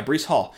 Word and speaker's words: Breece Hall: But yeah, that is also why that if Bryce Hall, Breece Hall, excuse me, Breece or Bryce Hall, why, What do I keep saying? Yeah, Breece Hall.